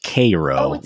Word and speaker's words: Cairo: First of all, it is actually pronounced Cairo, which Cairo.